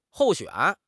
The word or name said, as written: 候选